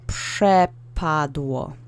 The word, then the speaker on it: przepadło: So, przepadło. Okay? And we have a w letter in the alphabet in the last syllable. So the accent is on the middle syllable.